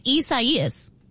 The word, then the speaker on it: Isaias: I-S-A-I-A-S. – And it's four syllables. I do know that. I-S-A-I-A-S. And it's like I-S-A-I-A-S.